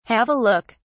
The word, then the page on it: have a look: British English